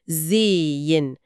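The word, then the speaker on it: sehen: إحنا مش بننطق الهاء أبداً، النطق الصحيح هو زين مع مد في صوت الإي.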